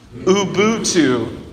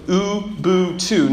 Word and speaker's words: Ubuntu: Ubuntu. Ubuntu.